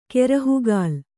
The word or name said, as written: ಕೆರಹುಗಾಲ್